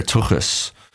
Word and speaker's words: tuchus: Just to pronounce it is harder. Tuchus. It sounds more German and, you know, Yiddish.